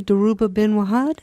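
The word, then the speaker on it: Dhoruba bin Wahad: And now, am I pronouncing his name correctly, Daruba Bin Wahad? – Sure.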